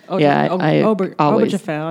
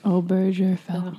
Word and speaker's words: Obergefell: I don't think that's how that's pronounced. – Obergefell, I think it is. – Yeah. – Obergefell.